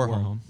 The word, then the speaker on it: Warholm: When Karsten, how do you pronounce his last name? – Warholm.